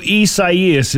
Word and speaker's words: Isaias: So Isaias is the correct pronunciation of it.